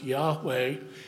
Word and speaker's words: Yahweh: And yet the God of Israel, whose name was traditionally Jehovah, more recently pronounced Yahweh, and shown in our scriptures with the four letters, L-O-R-D, all in caps.